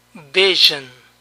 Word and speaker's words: vision: Este símbolo es más largo y representa el sonido sh, sh, sh. El mismo sonido que usan los argentinos para pronunciar la L en pollo, calle. Visión. Vision, vision, vision.